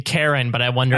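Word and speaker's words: Charon: And Karen is the ferryman to the underworld, spelled either C-H or K-H. I spelled it K-H so I can remember that it's pronounced Karen.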